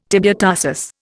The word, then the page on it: tibiotarsus: Indian